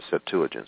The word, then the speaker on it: Septuagint: But anyway, the word ecclesia is also translated in the Septuagint, or the Septuagint. Some people pronounce it that way.